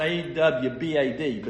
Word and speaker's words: abad: The term abad It's pronounced A-W-B-A-D, but it's spelled A-B-A-D.